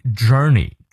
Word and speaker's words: journey: There's meaning in every journey。 journey 重音在前啊，接下来 that 继续咬舌，that is unknown 重音在第二个音节，that is unknown to the traveler。